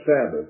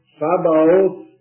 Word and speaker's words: Sabaoth: Don't pronounce that Sabbath. This isn't the Lord of the Sabbath at all. Sabaoth is the word, the Hebrew word, which means host.